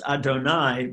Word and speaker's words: Adonai: And that's what Yud-Heh-Vav-Heh, which we pronounce Adonai, but it's not, those four letters don't add up to anything, and that's why Jews don't never pronounce it, because it's not about a name of a being, it's about a concept.